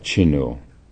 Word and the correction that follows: Chinul: Uh, I've been pronouncing it Chinu, uh, and then uh, I found another book uh, about him um, that it spells it uh, with a J instead of a CH. J I N U L. Jinul. So I don't know how it's pronounced, but uh, let's sort of soften that a little bit now. Let's make it uh, Chinul.